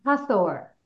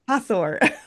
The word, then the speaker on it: Hathor: How do you pronounce it again? – Hathor. – Hathor. I want to make sure I get it right.